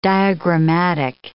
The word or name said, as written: diagrammatic